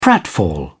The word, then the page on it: pratfall: British English